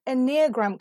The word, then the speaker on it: Enneagram: Now, amongst other things, you are an Enneagram coach, if I pronounced that correctly.